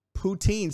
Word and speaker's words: poutine: How do you say it, by the way? Am I saying it wrong? I feel like I'm saying it wrong. I'm probably definitely saying it. I'm 100% saying it wrong. But that guy said Putin, but I don't think that's right either. All right, here you go. Here is the Putin. Poutine. See, that's what I was saying, but the sounds like didn't say that.